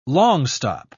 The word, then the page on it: longstop: British English